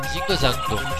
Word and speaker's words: Zinga Zanga: Zinga Zanga. – Zingo Zango, c'est comme ça qu'on prononce. – Mais pourtant je vois des A. – Oui, mais c'est à l'occitane et les A finaux se prononcent.